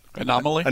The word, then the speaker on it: anomaly: Anomaly? – Anomaly. There you go. I was going to put an extra syllable in there.